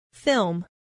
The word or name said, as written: film